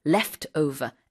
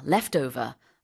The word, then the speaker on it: Leftover: 그래서 lap over라는 표현은 없는데 이제 어쩔 수 없이 우리말로는 left 이렇게 말을 하다 보면 우리 F 발음이 없으니까 이게 P인가? 이렇게 착각하실 수 있어요. – 네, 네, 네. – 이렇게 한국 프로그램 보다 보면은. 근데 이제 저희가 지금 말씀드리겠습니다. Leftover. L-E-F-T. 그 다음에 띄고 O-V-E-R. Leftover.